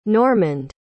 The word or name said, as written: Normand